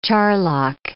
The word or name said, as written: charlock